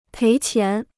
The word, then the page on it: 赔钱: péi qián